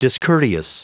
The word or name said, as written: discourteous